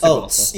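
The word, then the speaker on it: Tsagualsa: I'm pretty sure it's Sigwalsa.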